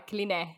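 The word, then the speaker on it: Cline: Ernest Klein, no niin. – Joo. tai Kline, Kline. tälleen niin kuin me lausutaan ihmisten nimet, niin se on Ernesti Kline.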